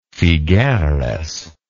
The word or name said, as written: figueres